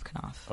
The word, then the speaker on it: Knopf: Yes, for Vintage, which is the paperback arm of Knopf.